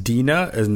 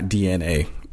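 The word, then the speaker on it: Dinamo: He let us know, I think, an episode ago that it's pronounced Dina as not DNA.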